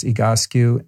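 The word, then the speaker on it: Egoscue: There is a kind of a physical therapy program called EGOSCU, spelled EGOSCU. S-C-U-E, EGOSCU, pronounced EGOSCU, and I'll put that in the show notes as well.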